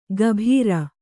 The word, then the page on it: ಗಭೀರ: gabhīra